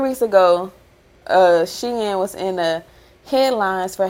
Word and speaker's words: Shein: Shein, or how do you pronounce it? Is it Shein or Sheen? – Shein. Yes.